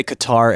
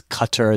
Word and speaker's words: Qatar: I think it was in Qatar, because we say Qatar Airways. It's Qatar is how they pronounce it.